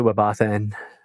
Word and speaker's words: WebAuthn: Yeah, I pronounce it WebAuthn for sure.